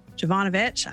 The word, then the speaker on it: Jovanovich: I am super, super excited because today I'm going to be talking to Jessica Jovanovich.